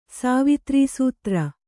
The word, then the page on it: ಸಾವಿತ್ರೀಸೂತ್ರ: kannada